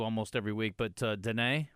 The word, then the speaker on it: Diné: they and it's D I N E. It's like this little accent mark, you know, over mm-hmm. the E, and it's pronounced Diné.